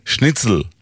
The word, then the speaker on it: שניצל: אולי גלשתי מעט להגייה הצברית. במקור אין תנועה לפני האות למד. נתח קטן, שניצל. עוגת התפוחים, שטרודל.